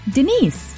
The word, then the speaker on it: Dinis: Well, it is spelled D-I-N-I-S. And yes, Tim, it has been spelled and misspoken before. – Right. – So it's... – If pronounced Dennis, then it rhymes with something else. – Yeah, so it's D-I-N-I-S, which is Denise.